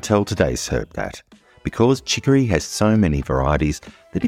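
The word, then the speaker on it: chicorée: Chicory. Chicory is spelt C-H-I-C-O-R-Y. And in French, it's pronounced chicory. But it's spelt C-H-I-C-O-R-E-E. But the first E has an accent above it.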